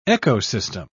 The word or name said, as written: ecosystem